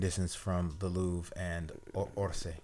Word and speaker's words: Orsay: Orsay. O-R-S-A-Y.